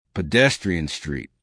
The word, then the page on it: pedestrian street: American English